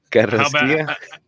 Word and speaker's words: Carrasquilla: He's got Fiera as the starter, Quinones on the left wing, Bassi on the right, Herrera and Corsicilla, and I'm sorry I mispronounced it. As far as the Corsicilla. Oh, man. Carrasquilla? You're talking about Carrasquilla, right? – Something like that, yeah.